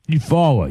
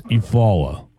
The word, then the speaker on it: Eufaula: Eufaula, yes. Eufaula. – Yeah, Eufaula. You say Eufaula. That's how you say it.